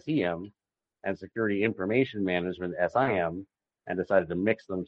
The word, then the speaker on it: SIEM: So the SIM tool, pronounced SIM, SIM tools are the AWACS out there looking over your network, soaking up data, letting you see what's going on.